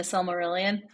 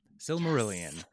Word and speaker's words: Silmarillion: Do you have to pull open the Silmarillion? Did I say that right? – You did. Good job. Silmarillion.